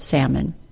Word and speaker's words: salmon: do not say the L sound in the word salmon.